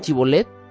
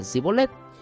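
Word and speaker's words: Shibboleth: Los primeros decían Chibolet, mientras que los otros decían Sibolet.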